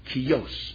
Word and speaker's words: Kjos: K-J-O-S. Pronounced Kios.